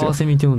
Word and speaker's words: 翡翠: カワセミって読むんだね。 – そう。翡翠って書いてカワセミって読むし、カワセミって書いて翡翠って読むんですよ。